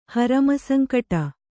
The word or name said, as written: ಹರಮಸಂಕಟ